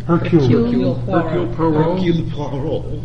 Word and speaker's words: Hercule Poirot: Hercule Poirot.